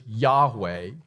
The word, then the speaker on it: Yahweh: So we would pronounce that in English, Yahweh.